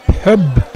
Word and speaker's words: pub: Men ordet pub uttales ofte pub, P-Ø-B-B på norsk. En fornorsking av den standardiserte uttalen som ligger nærmest pub med A, to B-er I stedet for Ø-en som vi bruker.